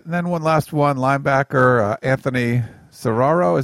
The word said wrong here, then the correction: Cerro: Is that how you say his name? – Anthony Cerro. – Cerro. Okay, sorry.